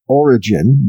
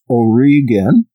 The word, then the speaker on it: Origen: Some people pronounce that Origen, but it's Origen.